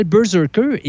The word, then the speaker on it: BRZRKR: Et il y a un comic qui est sorti au début du mois de mars qui s'appelle Berserker et qui s'écrit B-R-Z-R-K-R,